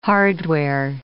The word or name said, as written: hardware